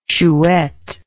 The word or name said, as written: chouette